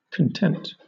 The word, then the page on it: content: British English